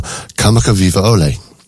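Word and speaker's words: Kamakavivaole (Kamakawiwoʻole): I actually wondered whether you'd chosen this just to see if I could pronounce his name. We've got Over the Rainbow. But it's actually by Israel Kamakavivaole. There, you're surprised, aren't you? I managed to say it.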